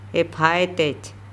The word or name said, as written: ephaetEc